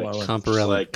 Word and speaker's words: Comporellon: C-O-M-P-O-R-E-L-L-O-N. – I have it spelled right. – Comparellon. Comparellon. – Comparellon. Comparellon. Okay. – Comparellon. – Okay. Let's try this again. – Elon like melon.